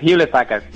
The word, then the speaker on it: Hewlett Packard: ¿cómo se dice exactamente? Hewlett, Hewlett Packard.